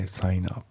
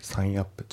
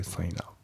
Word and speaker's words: sign up: あと発音ですけど、 sign up のところがリンキングで sign up だね。 sign up じゃなくて sign up。